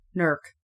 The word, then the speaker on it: Newark: That's the wrong pronunciation. – Okay, please enlighten people how it's said. – Newark. – It's Newark.